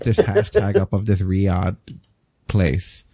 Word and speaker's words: Riyadh: Riyadh." Is that how you say it? Riyadh? – Yeah.